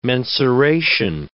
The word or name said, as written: mensuration